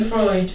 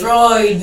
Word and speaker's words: Freud: I don't know how to pronounce his name. – Freud. – Yeah, you say it out loud again. – Freud. – Thank you. – Freud.